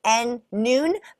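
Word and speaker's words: Nuun: And it literally is pronounced N-Noon, but it's N-U-U-N.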